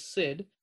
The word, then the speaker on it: Sid: So my first name is Sid, S for Sam, I, D for David.